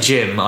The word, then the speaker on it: GM: By the way, when I say Jim, I mean GM, the you know, the mobile suits, GM, the grunt suits. It's pronounced Jim because we decided.